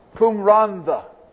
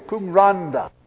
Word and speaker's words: Cwm Rhondda: The tune, and I hope I pronounce it correctly, Cwm Rhondda. Any Welshmen here? Is that the way you say it? Cwm Rhondda. Thank you. Cwm Rhondda.